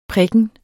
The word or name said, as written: prikken